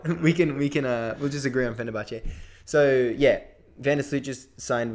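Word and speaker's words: Fenerbahçe: How do you pronounce that? – Yeah, I'd agree with that. I say Fenerbahce or Fenerbahce. – Okay, let's go with Fenerbahce.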